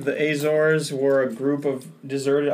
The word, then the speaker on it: Azores: And you didn't say it right, though. It's Azores.